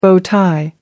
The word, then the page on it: bow-tie: American English